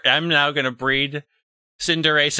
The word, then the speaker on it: Cinderace: But in my brain, I think Liberace. And so I pronounce it as Cinderace.